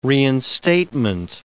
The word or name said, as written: reinstatement